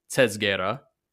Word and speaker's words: Tsezguerra: it is t- t- like T S. – Yeah, yeah. – Tezguera. – Tetscara. – No, no, no. There's no. Uh. It, it, it really doesn't matter. I'm just clarifying for the readers right now, uh, for the listeners, and then we can all pronounce it however we want. But it's Tetscara.